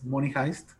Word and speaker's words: Money Heist: Money Heist, no sé si lo estoy pronunciando bien, Mr. David. – Pero, Perfecto.